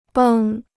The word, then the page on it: 绷: běng